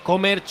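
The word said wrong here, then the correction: Cömert: Se dice Eray Chömert.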